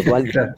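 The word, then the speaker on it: Duhalde: sé si se dice así, Dualde o Dujalde, pero, pero bueno, en español la H es muda, entonces por eso digo Dualde.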